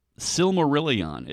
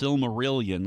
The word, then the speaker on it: Silmarillion: Silmarillion, it's not pronounced Silmarillion like we say million. That's not how it's supposed to be pronounced, and I just figured this out. It's sort of like when you say Necronomicon, the Silmarillion. It's about the Silmarils. You know, again, it's not a number.